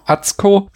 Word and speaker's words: Atsuko: Atsuko. – Das U wird nicht gesprochen.